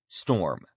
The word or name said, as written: storm